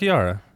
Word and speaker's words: Kakihara: Is it it's not Yaki. No, it's Kaki. Kakihara. – It's Kakihara.